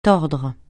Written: tordre

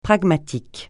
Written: pragmatique

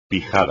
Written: pijada

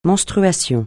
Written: menstruation

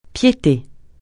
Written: piété

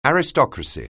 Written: aristocracy

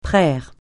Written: praire

